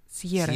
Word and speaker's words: Сьерры: Сьерры, да, наверное, ударение, я так понимаю? – Сьерры. – Сьерры, Сьерры. – Да? – Ну, испанец, да.